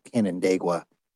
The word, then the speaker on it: Canandaigua: He's also vice president and a wealth advisor at Canandaigua.